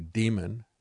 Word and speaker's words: Dimon: And so now we look at what is happening there, and you've got a little bit of an outrage and complaint because Jamie Dimon, or actually Demon, is probably a better way to pronounce his name, he got into Hong Kong without following any of the mandatory 21-day hotel quarantine.